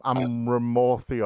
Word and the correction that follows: Amaranth: or whatever, what is it, Amaranth.